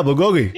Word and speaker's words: bulgogi: Yeah, bulgogi. – Yeah, of course. – I didn't realize it was pronounced that way. I always pronounce it very Western. I call it bulgogi.